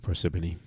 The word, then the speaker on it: Parsippany: So the two of them were staying at Howard Johnson Motel in Parsippany, New Jersey. So in March of 1993, we're in New Jersey. That's where our story is taking place. – Yeah, you said that right too, Parsippany.